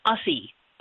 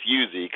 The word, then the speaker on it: ussy: An I think ussy. so. I don't think so. it's, I don't think it's Uzi because I think it's you know we're talking about us.